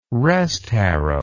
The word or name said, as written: restharrow